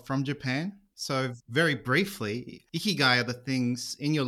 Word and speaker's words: Ikigai: Mate, is that how I say it, Ikigai? – That's how you say it.